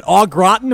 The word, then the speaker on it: au gratin: But the potatoes, I still don't know how to say it. Au gratin? Am I getting that right, Lee? – You got it right.